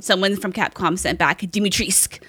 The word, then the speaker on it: Dimitrescu: So Dimitrescu, yes, Dimitrescu, according to Capcom, the U is silent.